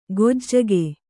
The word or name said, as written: ಗೊಜ್ಜಗೆ